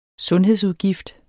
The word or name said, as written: sundhedsudgift